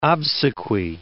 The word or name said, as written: obsequy